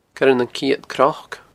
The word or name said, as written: Coire nan Ceud Cnoc